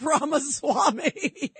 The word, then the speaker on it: Ramaswamy: I thought Bamaswamy, Dimmy Dammy, whatever. I can't say his name. – Vivek Ramaswamy.